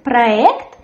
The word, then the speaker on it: проект: In the word проект, the stress as well falls on the last syllable. There's a firm r, проект. We write soft e, but we say проект. Of course, you can hear maybe sometimes проект, but it is very rarely.